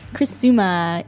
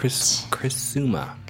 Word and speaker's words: Criciúma: Criciúma in Santa Catarina State. – Criciúma. – Criciúma. – How do you spell that? – C-R-I-C-I-U-M-A.